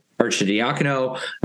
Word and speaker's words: Arcidiacono: your twin Ryan Arcidiacono, you know, that whatever with that.